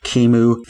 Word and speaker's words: Kimu: Kimu Theodore. My first name is spelled K-I-M-U.